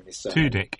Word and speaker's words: Tudyk: Tudyk, yeah.